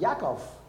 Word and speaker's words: Yaakov: Getting back to the Bible passage, James was a younger brother of Jesus, and his name is pronounced Yaakov, in case you're wondering.